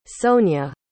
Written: sonia

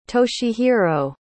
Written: toshihiro

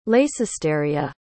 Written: leycesteria

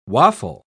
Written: waffle

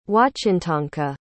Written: wahchintonka

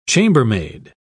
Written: chambermaid

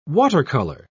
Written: watercolor

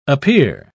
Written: appear

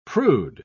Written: prude

